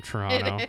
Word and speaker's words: Toronto: Oh, it's got to be The Man from Toronto. – It is. Or how they say it, Toronto. – Second T is silent, guys.